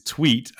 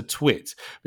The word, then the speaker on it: tweet: And it's the way you pronounce it is tweet and not a twit, because a twit is somebody who's a little bit silly, right? – Yeah.